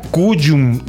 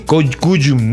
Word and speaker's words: Cullum: J-A-M-I-E. – Y se apellida Cuyum. C-U-L-L-U-M. Cuyum. No con Y, sino con doble L. – C-U-L-L-U-M.